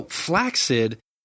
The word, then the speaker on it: flaccid: So flaccid, that was the original way it's pronounced. But when the movie Lake Placid came out, people started saying flaccid like placid.